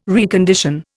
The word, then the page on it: recondition: Indian